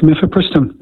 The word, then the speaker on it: mifepristone: Is it mifepristone? Did I pronounce that correctly? – That's correct, mifepristone.